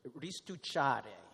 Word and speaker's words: restucciare: Restucciare is how you say it.